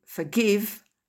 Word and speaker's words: forgive: To forgive, I forgave, forgiven. Donc, ces trois verbes qui commencent par F-O-R, l'accent tonique est en deuxième position. Il n'est pas sur le F-O-R.